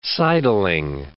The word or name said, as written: sidling